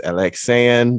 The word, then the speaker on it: Alexann: Miss Alexann.